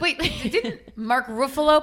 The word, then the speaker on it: Ruffalo: It's Ruffalo, like Pretty Buffalo.